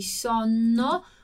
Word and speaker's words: sonno: Ho sonno, qua doppie consonanti, quindi sonno, pronuncia più lunga qua nella doppia, quindi sonno, sonno.